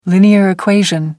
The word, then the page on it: linear equation: British English